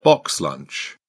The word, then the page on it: box lunch: British English